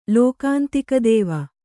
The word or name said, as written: ಲೋಕಾಂತಿಕದೇವ